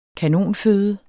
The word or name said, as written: kanonføde